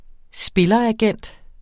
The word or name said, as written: spilleragent